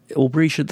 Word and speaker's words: Aubretia: Aubrietia, that is the right way to pronounce it, is it? Because I've seen it spelt in two different ways. – Yes, I think you've pronounced it correctly.